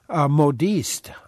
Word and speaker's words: modiste: You used the term modiste.